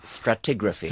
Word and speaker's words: Stratigraphy: How do you pronounce that? – Stratigraphy. – Stratigraphy, thank you.